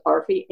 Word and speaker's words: ARFE: So for over 30 years, our commission has had something called, we pronounce it ARFE, Annual Report on Finance and Enrollment, and it's data forms on finance and enrollment and the audit and a narrative.